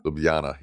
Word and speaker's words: Ljubljana: I never know how to say this name. Ljubljana? – Ljubljana? – Ljubljana, yeah.